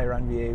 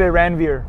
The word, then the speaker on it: Ranvier: It's a French guy, Ranvier, who figured it out. So all the Americans say Ranvier. – Ranvier. – Yeah, Ranvier. Like, Ranvier. Indians would say Ranvier.